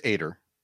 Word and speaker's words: Ater: It's Ater. It's good. – Ater as in Gator.